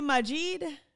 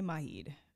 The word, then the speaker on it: Majid: That was Maheed Jordan, and the song is called Superstar. And Maheed Jordan is out here just selling dreams to women. Some say Majid Jordan. I say Maheed, you know, to each his own. It's spelled M-A-J-I-D, Jordan.